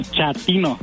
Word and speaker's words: chatino: Chapino. – ¿Chapino? – No, chatino. – Es como chato, ¿verdad? Chatino. – Sí, azunero, como chato, chatino.